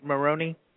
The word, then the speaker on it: Moroney: Did I say your name right, Moroney? – That's correct. Absolutely. It's a little bit of an Irish descent, actually, with Moroney. Kind of sounds like Italian sometimes, but it's actually Irish.